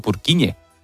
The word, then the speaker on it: Purkinje: En español sería Purkinje. – Purkinje. – Pero Purkinje es en checo, que es por porque es el apellido del descubridor.